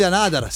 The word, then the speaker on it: Anotr: Loro sono gli Anadar, segnatevi, Anotr si scrive, Talk to You è la traccia, altro bel pezzo.